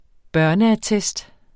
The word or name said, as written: børneattest